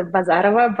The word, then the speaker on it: Bazarova: My full name is Elizaveta Bazarova, but Liz works, and my last name works too.